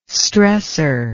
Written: stressor

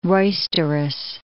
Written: roisterous

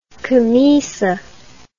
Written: camisa

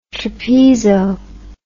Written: trapezo

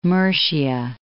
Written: Murcia

